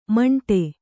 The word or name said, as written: ಮಿಂಡಿ